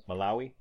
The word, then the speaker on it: Malawi: Malawi.